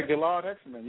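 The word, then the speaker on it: Gilad Hekselman: Oh, yeah, Gilad Hexelman. Yep, that's the right way to say it.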